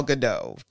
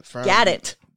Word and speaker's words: Gadot: So yeah, so we've got people like Shirley MacLaine to Gal Gadot to... – Gadot.